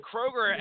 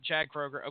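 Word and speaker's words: Kroeger: am I pronouncing that right, by the way? Like, Kroger's like the store? Is that how you guys would pronounce it, Chad Kroger? Okay, all right. It's not K. Roger, like my aunt used to call Kroger's down here in Marietta. She used to call it K. Rogers.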